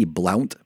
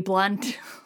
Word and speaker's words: Blount: Okay, you'll love this because it's actually pronounced Lady Blunt. – No, I deliberately didn't pronounce it that way because there's no way it's Lady Blunt. – It's a very polite American sensibility, but no, it's literally Lady Blunt.